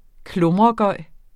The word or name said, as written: klummergøj